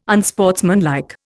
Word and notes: unsportsmanlike: Indian

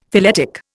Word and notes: phyletic: Indian

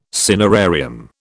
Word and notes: cinerarium: British English

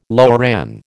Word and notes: loran: American English

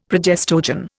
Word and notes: progestogen: Indian

